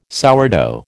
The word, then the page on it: sourdough: American English